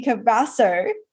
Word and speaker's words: Cabasso: I have Andy Cabasso.